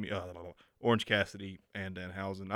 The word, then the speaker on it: Danhausen: Yeah, um, uh, I think the biggest takeaway from this night is that Orange Cassidy and how do you say his name? – Danhausen. – Danhausen.